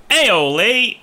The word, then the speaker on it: Aioli: Alioli. Aioli. Is that how you pronounce it? – Aioli. – Aioli. – It's like you're saying hello to your relatives. – It's not alioli, but yeah.